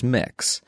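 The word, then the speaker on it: Mx: and that is mix. And it's spelled when you're using it as a title M, capital M, lowercase x, period. If you were to spell it out as a word, it would be mix M I X, capital M, lowercase i, lowercase x.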